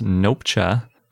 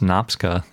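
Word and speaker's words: Nopcsa: And our fun fact is about Franz Nopcha, not pronounced Nopska the way we usually say it.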